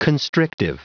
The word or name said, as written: constrictive